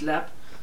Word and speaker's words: Leap: but it's pronounced Lepp because they leapt from it. Lepp Castle built in 1250.